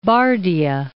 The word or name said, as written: Bardia